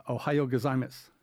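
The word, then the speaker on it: Ohio gozaimasu (おはようございます): Ohio gozaimasu.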